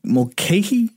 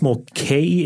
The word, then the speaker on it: Mulcahy: How do you say his name? Mulcahy? – It's I believe it's Mulcahy. It's M-U-L-C-A-H-Y.